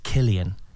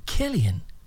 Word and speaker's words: Killian: Killian, Kelvin, Klein. I get all the different versions of my name. So when I finally go, it's Killian, in auditions or anything, Killian.